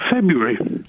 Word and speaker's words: February: Certainly beware of the Ides of February. – Listen, how many R's are there in February? – Not as many as you just said. – I've said two. No one pronounces the first R.